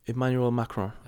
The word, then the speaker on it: Emmanuel Macron: Emmanuel Macron.